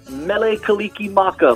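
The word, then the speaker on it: Mele Kalikimaka: Mele Kaliki Maka, my friend.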